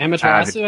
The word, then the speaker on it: Amaterasu: Is that how you say it? – Mm-hmm. Amaterasu, I think it is.